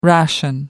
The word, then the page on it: ration: American English